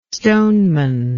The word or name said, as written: stoneman